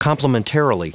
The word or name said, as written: complimentarily